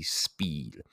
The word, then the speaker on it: spēd: Speed itself originates from really the old English speed, but it's spelled S-P-E-D, like sped, with a macron over that E, making it a long E, right? So that would be speed, meaning success, prosperity, or good fortune in that old English.